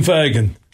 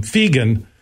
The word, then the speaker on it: Fagan: From Jeff Moneyshot Morton and Martin Scorsese Boer at the Collaborative Lab to Tim Fagan, which I used to call him Fegan. And then he go, no, it's Fagan and it rhymes with Reagan.